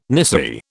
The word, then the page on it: nisei: British English